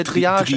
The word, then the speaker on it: Triage: aber den ersten Anime, den wir uns heute Abend zu Gemüte gezogen haben, das war Dreierk X. Ja? Nee, ich so das nicht ausgesprochen. – Äh, wie wird's denn richtig aus? Äh, Triage. Triage. Äh, Triage. – Das ist Triage. ein französisches Wort.